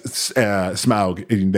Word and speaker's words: Smaug: Smaug. Thank you for pronouncing it correctly.